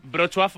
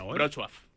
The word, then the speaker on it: Wrocław: Wrocław